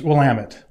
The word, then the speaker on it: Willamette: Willamette? – Willamette. – Okay. So see, the Southern Illinois guy says Willamette. – Yeah. It's Willamette. – Yeah. Well, okay. That's how it's supposed to be pronounced.